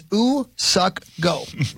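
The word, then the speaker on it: Go Woo-suk: It's the pronunciation is Usuk Go.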